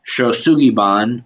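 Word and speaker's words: Shou Sugi Ban: And it's an ancient Japanese wood charring technique called Shosugiban, which is kind of a Western pronunciation of a native Japanese word called Yagisugi.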